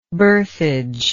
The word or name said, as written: berthage